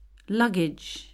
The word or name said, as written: luggage